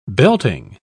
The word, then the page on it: belting: British English